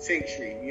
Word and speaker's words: XNTRY: It's like pronounced Sanctuary.